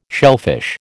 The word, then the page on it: shellfish: American English